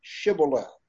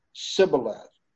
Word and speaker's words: Shibboleth: If he said no, then they would say to him, "Okay, say now Shibboleth." But he said Sibboleth, for he could not pronounce it correctly.